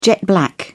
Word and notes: jet black: British English

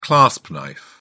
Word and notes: clasp knife: British English

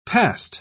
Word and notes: passed: British English

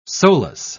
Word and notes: solus: British English

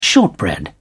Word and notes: shortbread: British English

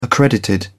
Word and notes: accredited: British English

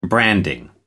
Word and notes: branding: American English

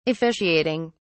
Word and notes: officiating: British English

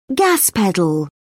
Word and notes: gas pedal: British English

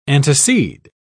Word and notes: antecede: British English

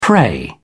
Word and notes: prey: British English

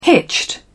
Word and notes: hitched: British English

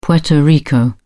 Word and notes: Puerto Rico: British English

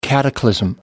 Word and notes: cataclysm: American English

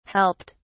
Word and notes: helped: British English

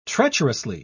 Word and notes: treacherously: British English

